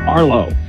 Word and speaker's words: Arlo: Yeah, hi, my name is Arlo. I wanted to talk to Drew. – What's your name again? – Arlo. – How do you spell that? – A-R-L-O.